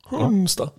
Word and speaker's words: Hunstad: Hunstad. – Hei, Anders. – Han uttaler ikke navnet sitt sånn som jeg gjorde det nå. Hunstad. Han uttaler det helt vanlig.